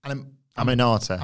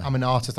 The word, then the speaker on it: Aminata: This wasn't a bad night for Queen Aminata. – Aminata, thank you.